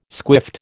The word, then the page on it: squiffed: American English